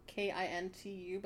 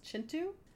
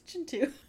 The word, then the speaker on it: Kintu: Like, I just finished the book spelled K I N T U, but it's pronounced Chintu. – Oh, I thought it was. I. legitimately thought it was Kintu. Yeah, well, that's what I learned in the beginning of the book. It's Chintu.